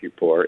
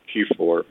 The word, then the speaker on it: Kippur: We pronounce it Kippur. It could be Kippur, but it's the same word for pitch.